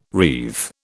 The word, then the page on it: wreathe: British English